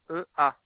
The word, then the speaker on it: Cerea: Alors, le nom s'écrit C-E-R-E-A. – REA. Seria.